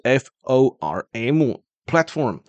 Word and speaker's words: platform: platform 就是 plat P-L-A-T，然后那个 A 是念成苹果 A，然后后面再一个 form，也就是 F-O-R-M platform。